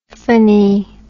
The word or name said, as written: phany